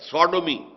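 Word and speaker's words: Sodom: سوڈومی سودوم سودوم وہ شہر تھا سدوم جس کو ہم کہتے ہیں سوڈوم S O D O M یہ بائبل میں اس کا تلفظ ہے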